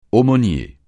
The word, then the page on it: aumônier: francais